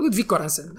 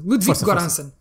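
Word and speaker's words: Ludwig Göransson: É Ludwig Goransson. Acho que eu estou confiante nesta minha pronúncia. – Ludwig Goransson.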